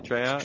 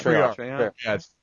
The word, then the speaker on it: Treyarch: So, basically, Activision and Treyarch have I pronounced that correctly? Is it Treyarch? – Treyarch. – I don't know, because, I mean... – It's Treyarch. Get it right.